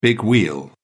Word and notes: big wheel: British English

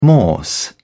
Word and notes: morse code: British English

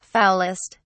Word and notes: foulest: British English

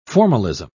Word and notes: formalism: British English

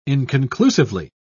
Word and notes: inconclusively: British English

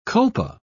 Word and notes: culpa: British English

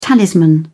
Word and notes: talisman: British English

